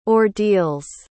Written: ordeals

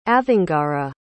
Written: avongara

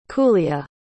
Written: Kulya

